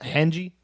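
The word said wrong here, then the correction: Hange: Hanji Zoe's great.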